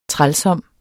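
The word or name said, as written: TRÆLSOMT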